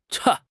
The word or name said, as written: cha1